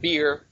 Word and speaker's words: Be'er: So B-E-E, not beer, okay? Not that kind of well. This is a B-E-R. Be'er is pronounced.